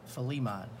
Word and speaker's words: Philemon: The best way I can think of to remember is take the word Philly and just take the emphasis on the Li part, Philemon.